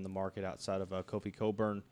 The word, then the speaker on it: Cockburn: Do you know, not to interrupt you, but I had no idea that's how you pronounce his name the other day, and I called Steve up. I was like, what about that Cockburn kid?